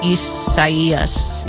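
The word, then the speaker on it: Isaías: Well, no, but it's probably the Spanish rendering of it, which in case if that's the case, then the I is pronounced like an E. – Isaias.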